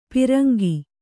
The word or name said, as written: ಪರಂಗ